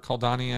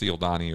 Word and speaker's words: Cialdini: Robert Kaldani, I think. Yes, Dr. Robert Cialdini, C-I-A-L-D-I-N-I. So I think that's spelled, I think that's pronounced Cialdini or Cialdini.